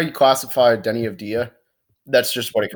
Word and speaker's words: Deni Avdija: Of Dia, Deni Avdija. – It's a Deni Avdija.